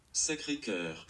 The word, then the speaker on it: Sacré-Cœur: Spreche ich das richtig aus? Sacré-Cœur. Sacré-Cœur. Ich sage Sacre, das heißt aber Sacré-Cœur anscheinend.